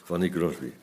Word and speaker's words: Fanny Crosby: Fanny Crosby. Toch? Zeg ik het goed? Ja, hè? Fanny Crosby.